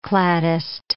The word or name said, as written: cladist